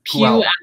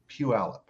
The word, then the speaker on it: Puyallup: Puyallup? How do you say that? – Puyallup. – Puyallup. – There you go. – Puyallup.